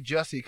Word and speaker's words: Jussie: And I say Jussie because his name is spelled J-U-S-S-I-E.